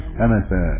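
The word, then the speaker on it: أنسًا: فيكتبون سمعت أنس، ينطقونه أنس، سمعت أنس، ويكتبونه أنس بغير ألف، وكذلك مالك كتبوه بغير ألف ويقرؤونه بالنصب.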